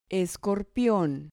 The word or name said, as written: escorpion